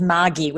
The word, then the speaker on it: Margie: It's the Australian pronunciation is Margie with a hard G.